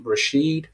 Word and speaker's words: Rasheed: So if you would just like to get connected with me, just go on BetterHelp and type in my name, Rashid. It's spelled R-A-S-H-E-E-D. And then my last name, Ojerinde Date, which is O-J-E-R-I-N-D-E.